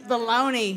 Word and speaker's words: Bologna: Take the sliced meat that has a first name, O-S-C-A-R, and pair it with a traditional Italian vegetable soup. – Bologna. – Pronounce it with less class. – Yes. – Pronounce it like you didn't go to cooking school. – Bologna.